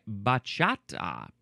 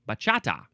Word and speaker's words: bachata: Now, how do you pronounce it? In English, we say bachata. Bachata. Yep, that's pretty good.